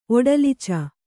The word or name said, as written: ಒಡಲಿಚ